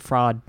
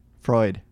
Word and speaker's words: Freud: Yeah, Sigmund Freud came up with it. – Sigmund Freud? – Yes. – Freud. – Yeah, I know. – Oh. – I realized I said it wrong after I said it.